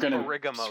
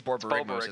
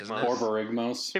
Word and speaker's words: Borborygmos: So I don't think it's pronounced Barigamos. – Screw you. – I think it's Borborygmos. – Borborygmos.